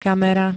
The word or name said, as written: kamera